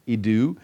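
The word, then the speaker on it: idu (ἰδού): The transliteration or the pronunciation of the word is idu, and that word is used by Matthew numerous times over the next couple of chapters.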